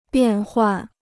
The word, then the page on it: 变换: biàn huàn